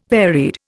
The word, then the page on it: varied: Indian